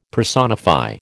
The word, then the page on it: personify: American English